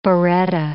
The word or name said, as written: biretta